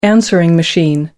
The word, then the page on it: answering machine: American English